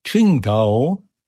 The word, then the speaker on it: Qingdao: Das Q im Chinesischen wird wie Xi gesprochen. Es heißt also auch Qingdao.